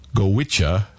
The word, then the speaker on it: Goitse: Let's close out this hour of music here with a traditional Irish quintet pronounced Goitse, but spelled G-O-I-T-S-E.